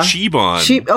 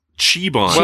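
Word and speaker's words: Zhaban: We got a proper pronunciation apparently here too. – Yeah, Shebon. – Okay. – Chibon. – Well, that's what the hologram says, but I think when his name is said elsewhere, it's said as Chibon.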